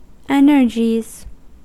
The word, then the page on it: energies: American English